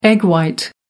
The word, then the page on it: egg white: American English